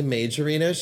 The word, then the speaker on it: Majorino: And Tina Margarino. How do you say it? – Tina Majorino? She's not margarine. – She's major.